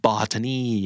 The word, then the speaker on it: botany: botany botany นะครับออกว่า botany หรือว่า botany ตัว TA บางครั้งก็แทบไม่ออกเสียงเลยนะครับ botany ก็คือวิชาพฤกษศาสตร์ส่วนพฤกษศาสตร์อะไรต่างๆเนี่ยก็จะใช้คำว่า botanical คุณไหมครับหรือว่าเครื่องสำอางที่มีความแบบสมุนไพรพฤกษศาสตร์ต่างๆก็จะเห็นคำนี้เนี่ย botany